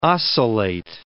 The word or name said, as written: oscillate